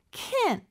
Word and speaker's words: can't: can't 같은 경우는요. 마치 can처럼 들릴 수 있지만 약간 콧소리가 들어갑니다. can't 이렇게. 그리고 마지막 t는 잘 안 들려요. 근데 끊어주는 느낌이 있어요.